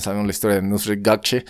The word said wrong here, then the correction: Nusret Gökçe: A ver, vamos a ver si lo estuve pronunciando bien. Nusret Gökçe. Nusret Gökçe.